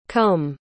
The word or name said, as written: côme